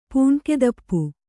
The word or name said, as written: ಪೂಣ್ಕೆದಪ್ಪು